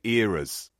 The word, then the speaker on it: Eras: Simon is saying Eras tour, the Eras, as in moments in time through history. As opposed to mistakes, which is how apparently our North American cousins are now referring to the word era.